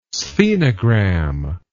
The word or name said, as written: sphenogram